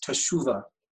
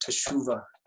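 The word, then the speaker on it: teshuva (תשובה): Just want to just share about this word repent in Hebrew is teshuva. Can you say that? Teshuva.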